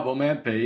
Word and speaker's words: Omanpe: This called the Omantpe license. Apologies if I'm mispronouncing that. – you did a good job, Omantpe. Yep, well done.